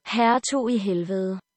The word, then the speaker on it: Hertug i Helvede: And according to Google Translate, it's pronounced Hertug I Helvede.